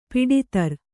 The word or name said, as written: ಪಡತರ